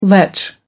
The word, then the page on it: lech: American English